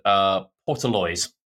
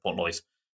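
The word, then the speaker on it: Portlaoise: So, there's an Irish basketball game between Limerick and Portaloise. – Okay. – Or Portaloise. I think that's how it's pronounced, Portaloise.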